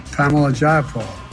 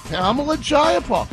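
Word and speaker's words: Pramila Jayapal: I think, sir, you owe me an apology because I used to mispronounce her name until I found out the correct way to pronounce it, and I just make sure I'll never screw it up again. – Pamela Jayapal.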